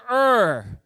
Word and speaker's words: Ur: Everyone say Ur.